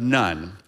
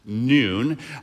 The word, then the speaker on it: Nun: son of Nun. Now, actually, that's pronounced Noon.